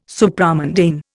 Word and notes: Supramundane: Indian

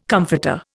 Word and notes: comforter: Indian

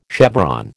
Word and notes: chevron: American English

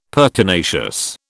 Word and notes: pertinacious: British English

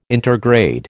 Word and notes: intergrade: American English

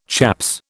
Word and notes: chaps: British English

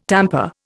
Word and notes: tamper: Indian

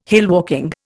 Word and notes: hillwalking: Indian

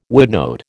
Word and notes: woodnote: American English

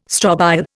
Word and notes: strobile: Indian

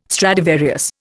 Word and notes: Stradivarius: Indian

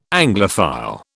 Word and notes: Anglophile: British English

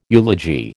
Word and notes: eulogy: American English

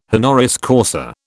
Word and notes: honoris causa: British English

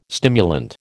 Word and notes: stimulant: American English

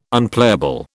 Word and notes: Unplayable: British English

